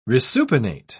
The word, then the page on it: resupinate: British English